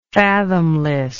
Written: fathomlessness

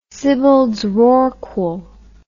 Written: sibbalds rorqual

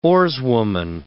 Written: oarswoman

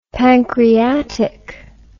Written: pancreatic fibrosis

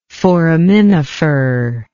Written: foraminiferous

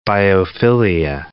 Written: biophilia

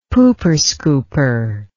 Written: pooper scooper

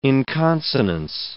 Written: inconsonance

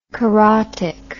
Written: carotic

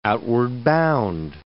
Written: outward-bound

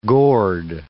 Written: gourd

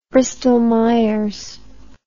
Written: Bristol-Myers

